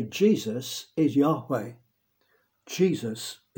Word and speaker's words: Yahweh: And we don't really know how it was pronounced. It may have been pronounced as Yahweh.